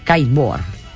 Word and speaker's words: kiteboard: En aquesta ja li hem donat diverses vegades també, però s'ho torna a mereixer perquè va guanyar el campionat del món, no sé si ho pronunciarà bé, del kiteboard. – Kiteboard, sí. – Ho he dit bé? – Sí, ho dius bé.